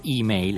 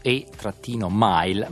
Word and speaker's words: email: Oggi e come email e qui dovete concedermi, dobbiamo pronunciarlo per bene, naturalmente email è scritto e-mile, ma dobbiamo pronunciarlo all'inglese perché come al solito gli inglesi ci hanno battuto e computer,